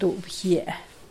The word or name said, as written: duubgie